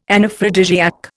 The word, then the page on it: Anaphrodisiac: Indian